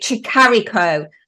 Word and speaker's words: Tricarico: It's Francesca Tricarico. Did I say that right? – Tricarico. – I've missed the C out of it. Tricarico.